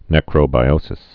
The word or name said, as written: necrobiotic